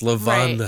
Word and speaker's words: Levana: It's Levana. Right. With one N. L-E-V-A-N-A.